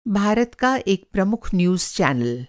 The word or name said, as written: न्यूज़ चैनल